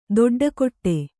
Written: ದಡ್ಡುಕಟ್ಟು

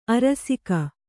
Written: ಅರಸಿಕ

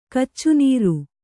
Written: ಕಚ್ಚುನೀರು

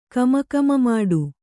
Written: ಕಮಕಮಮಾಡು

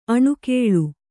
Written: ಅನಾಕುಲ

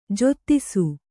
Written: ಜಾತಿಸು

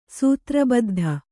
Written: ಸೂತ್ರಬದ್ಧ

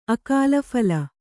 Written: ಅಕಾಲಫಲ

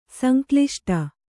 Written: ಸಂಕ್ಲಿಷ್ಟ

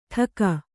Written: ಠಕ

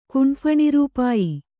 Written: ಕುಂಫಣಿರೂಪಾಯಿ

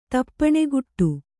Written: ತಾಪಣೆಗೂಡು

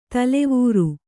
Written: ತಲವಾರ್

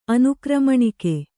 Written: ಅನುಕ್ರಮಣಿಕೆ

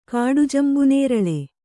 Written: ಕಾಡುಜಂಬುನೇರಳೆ

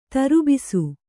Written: ದರ್ಬೇಸಿ

